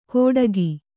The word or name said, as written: ಕೋಡಗಿ